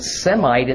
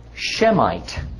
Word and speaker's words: Shemite: Actually, the word Semite itself is kind of an error. Okay. At least in the sense of how it's pronounced and spelled. The word should be Shemite. Okay. Not Semite.